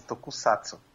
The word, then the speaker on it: Tokusatsu: É com S, Tokusatsu. – Tokusatsu, tá, isso.